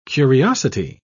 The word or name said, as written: curiosity